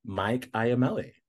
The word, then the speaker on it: Mike Iamele: Welcome to this episode of Queer Changemakers, and today we have Mike Iamele.